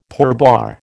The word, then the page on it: pourboire: American English